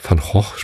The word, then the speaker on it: Van Gogh: Also nicht Kunst von Van Gogh, aber also Van Gogh spricht man ihn ja eigentlich aus. Aber ich glaube, in Deutschland ist Van Gogh üblicher.